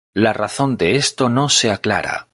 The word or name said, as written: aclara